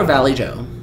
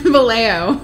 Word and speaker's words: Vallejo: Is it Vallejo or Valley Joe? How do you pronounce it? – Vallejo. – Vallejo.